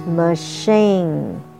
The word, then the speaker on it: machine: Okay, and next word we're going to learn is machine, machine, M-A-C-H-I-N-E, C-H pronounced as sh, machine, the pizza machine, pizza 高音在第一段, machine 高音在第二段.